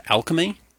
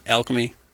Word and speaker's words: Alkeme: And it's, I believe it's pronounced Alchemy, would be my guess. – Like A-L-K-E-M-E.